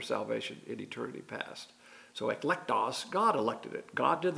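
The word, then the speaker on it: eklektos (ἐκλεκτός): The word for choice is ekleketos, which is pronounced eklektos in the Greek.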